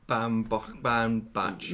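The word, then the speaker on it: Baumbach: Noah Bambach? I can never say his name properly. – Bambach.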